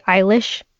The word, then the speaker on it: Eilish: Billie Eilish.